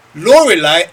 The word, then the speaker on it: Lorelei: How to spell that Pokemon name is F-I-R-E. Lorelei, L-O-R-E-L-E-I. Lorelei, that's how I pronounce it.